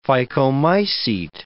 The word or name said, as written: phycomycete